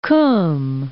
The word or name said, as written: Qom